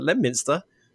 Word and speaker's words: Leominster: And it's when you folks come over here and you try and pronounce our names, you kind of, but Leominster, I'm going to remember that this time.